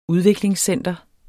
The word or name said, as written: udviklingscenter